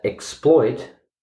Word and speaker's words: exploit: So there's two different pronunciations. One is the noun, exploit, or exploits, and one is the verb, exploit. So the stress is exploit for the noun, and exploits for the verb.